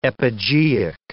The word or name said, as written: epigeic